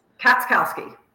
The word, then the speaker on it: Katschkowsky: Katschkowsky, Alison Katschkowsky.